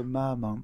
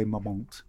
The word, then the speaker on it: Marmont: The Chateau, I say the Chateau Marmont, but I think some people say Marmont.